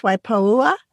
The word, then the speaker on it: Waipoua: Is it pronounced Waipoua Forest? – That's right.